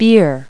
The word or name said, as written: beer